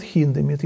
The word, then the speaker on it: Хиндемит: И вот она говорила, что вот кто-то сказал про Хиндемита, она говорила, вот в Германии говорят Хиндемит. Если вы говорите по-немецки, то надо говорить Хиндемит. Это немецкое произношение.